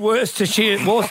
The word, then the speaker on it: Worcestershire: Celebrated my 50th over the weekend and tried Worcestershire sauce for the first time. My life will never be the same again. Is that how you say it? – Worcestershire. Like Leicestershire and Northamptonshire.